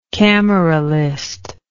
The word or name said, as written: cameralism